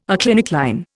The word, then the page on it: aclinic line: Indian